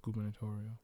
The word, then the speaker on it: gubernatorial: First, I want to talk about, how do you say, is it gubernatorial? – Mm-hmm. Gubernatorial.